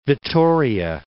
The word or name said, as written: Vitoria